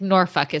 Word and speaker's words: Norfolk: Norfolk? Like Norfolk? He's like, Norfolk is how it's pronounced.